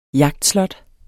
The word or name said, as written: jagtslot